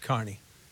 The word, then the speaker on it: Kearney: But for us, it's Carney. Yep. No matter what. – But it's spelled Kearney. Carney would be C-A-R-N-E-Y.